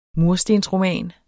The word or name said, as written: murstensroman